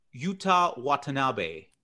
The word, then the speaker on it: Yuta Watanabe: Yuta Watanabe.